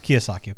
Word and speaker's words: Kiyosaki: Kiyosaki.